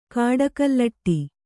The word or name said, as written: ಕಾಡಕಲ್ಲಟ್ಟಿ